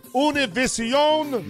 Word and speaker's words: Univision: I would recommend, with the fact that our debate last night was on Univision, or as the native tongue would pronounce it, Univision.